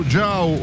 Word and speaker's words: Giau: e tra poco arriva il Passo Giau o Giof de Giau, se preferite la pronuncia ladina, e spero di essermi espresso al meglio possibile.